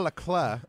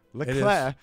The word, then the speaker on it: Leclerc: He bet on Ferrari driver Charles Leclerc. I think is how you say it. – It is. – Leclerc.